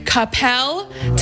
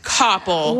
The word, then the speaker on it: Coppell: Is it Coppell, Texas, or is it Coppell? – Well, the first one you said is the closest. The second one sounds so wrong because it is. It's Coppell.